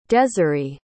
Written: deseree

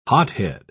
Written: hothead

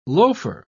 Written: loafer

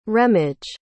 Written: remige

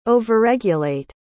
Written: overregulate

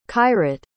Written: khayrat

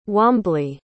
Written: wamblee

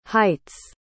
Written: heights

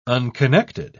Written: unconnected

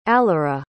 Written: allira

